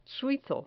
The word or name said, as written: suizo